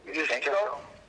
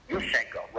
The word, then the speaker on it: Yushchenko: Yushchenko. – Yushchenko, right.